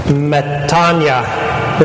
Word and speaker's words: metanoia: You can just kind of ignore the O in pronouncing it. Metanoia is like an E almost, because it's literal from the Greek.